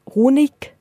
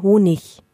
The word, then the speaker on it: Honig: man würde nicht Honig sagen, sondern Honig. Da spricht man immer ein Ich anstatt dem IG.